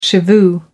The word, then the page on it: shivoo: American English